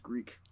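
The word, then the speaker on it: Greak: But let's move on to a game which I believe it's pronounced Greek.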